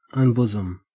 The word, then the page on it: unbosom: Australian English